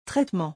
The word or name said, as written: traitement